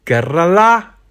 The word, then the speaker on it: Kerala: Kerala Zeg ik dat goed? Dat zeg je goed.